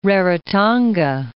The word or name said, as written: Rarotonga